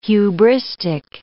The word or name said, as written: hubristic